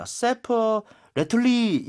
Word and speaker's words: separately: 와, 이건 발음이 진짜 중요할 것 같습니다. Separately 이게 아니에요. 그렇죠? – 네. – 영어에서는 으 하는 소리가 나오면 안 됩니다. So, separately. 이에다가 처음 이에다 강세를 좀 줘야 될것 같고요.